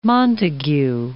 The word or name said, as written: Montague